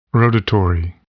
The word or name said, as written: rotatory